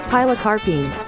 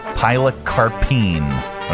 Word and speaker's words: pilocarpine: how do you pronounce this? – Pilocarpine. – Pilocarpine. Okay.